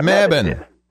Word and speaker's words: Mebane: Mebane? – Mebane is close, yeah. – Mebane.